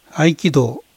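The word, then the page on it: 合気道: Japanese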